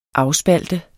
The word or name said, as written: AFSPALTER